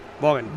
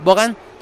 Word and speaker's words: Vaughan: Vaughan, o ¿cómo se dice, se pronuncia? – Vaughan. – Vaughan.